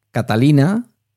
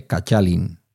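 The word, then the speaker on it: Kattalin: Por ejemplo, Catalina se escribe con K, con dos T's y sin la A final, pero no se lee Catalin, se lee Cachalin.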